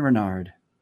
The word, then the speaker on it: Renard: Mr. Ryan Renault. Renard. Did I say it right? Ryan Renard.